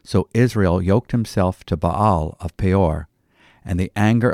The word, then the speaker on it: Baal: We read about Baal worship, otherwise pronounced Baal.